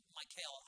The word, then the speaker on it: Michal: Here's Michal, I believe that's the way, Michal, I believe is the way you say her name.